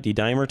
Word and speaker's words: D-dimer: My dad did a D-dimer. Am I saying that right? D-dimer test? – Yes.